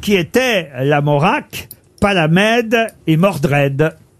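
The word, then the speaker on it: Lamorak: L'Amora. Pas la C'est de la moutarde. – Non. L'Amorac, pardon. Je dois prononcer le K, je crois, qui se trouve à la fin de l'Amora.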